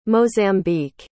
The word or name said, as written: mozambique